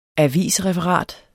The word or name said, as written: avisreferat